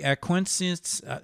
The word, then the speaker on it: acquiesces: When he acquiesces, how do you pronounce it? It's A-C-Q-U-I-E-S-C-E-S. – Acquiesce. – Yeah, when he acquiesces in his own being, whereas the essence of arcedia is the refusal to acquiesce in one's own being.